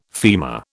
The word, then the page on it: femur: British English